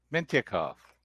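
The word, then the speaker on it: Mintyukov: From Anaheim, Pavel Minchekhev. – Close. Mintikov. – Mintikov.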